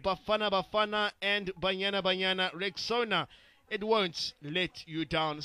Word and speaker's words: Rexona: Guys, I'm sure you've seen that Shield is now Rexona, but it appears that some people don't know how to pronounce this new name. A friend even called it Rexona. Guys, it's Rexona, like Rex Owner, Rexona.